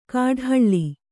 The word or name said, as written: ಕಾಡ್ಹಳ್ಳಿ